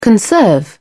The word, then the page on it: conserve: British English